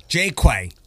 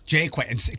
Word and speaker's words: Jaqua: So Jaqua. – Jaqua. Jaqua. Can you spell that? – It's J A Q U A. – Okay. When I kept trying to dig you up in my email so we could make this happen, I was like J A C. I'm like, where is Jen? – Well, it's it's French. – Okay. – And so my sister who lives in California, she will pronounce it the French way, Jaqua but I prefer Jaquay. Jaquay. Yes.